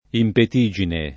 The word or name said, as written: impetigine